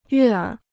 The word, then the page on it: 悦耳: yuè ěr